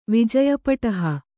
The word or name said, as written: ವಿಜಯಪಟಹ